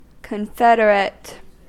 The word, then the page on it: confederate: American English